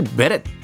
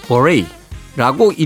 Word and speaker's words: beret: 핸드류 골드의 Never Let Her Slip Away 그리고 프린스의 Raspberry Beret 예, 이걸 사실 베렛 이렇게 읽었던 적도 있는데요. 어, 사전을 찾아보면 버레이라고 읽으라고 되어 있습니다.